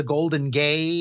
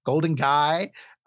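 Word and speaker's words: Golden Gai: So, that area is known as the Golden Gay, G-A-I. It's guy, Golden Guy.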